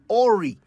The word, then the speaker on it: awry: I often hear people say Awry when they mean to say awry. A-W-R-Y, awry, deviating from a course or plan.